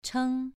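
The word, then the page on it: cheng: tone 2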